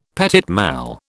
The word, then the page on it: petit mal: British English